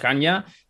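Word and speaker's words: Hannya: Y que, por cierto, tú hacías referencia a la máscara de Hanya, no sé si lo he pronunciado bien, es Hanya, ¿Puedes contar un poco cuál es la leyenda que hay detrás de esa máscara, que es también protagonista del juego? – Sí, vamos a ver.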